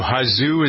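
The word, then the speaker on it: Hosszú: And so there are other, the swimmer, what was her name, Hazu, is that how you pronounce her name? H-O-S-S-Z-U. – She sounds right.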